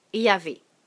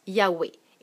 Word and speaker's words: Yahweh: Le nom de Dieu, c'est Yahvé. Ou, certains autres le prononcent Yahweh.